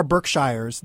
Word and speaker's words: Berkshire: There's there are Berkshires. There's yeah, there aren't. Well, you're from Massachusetts. – Yeah. – How do you say it? – Berkshire.